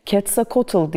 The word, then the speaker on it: Quetzalcoatl: Quetzalcoatl diye telaffuz edilen Q harfiyle yazılan tanrı bir yılan tanrısı ama tüylü bir yılan.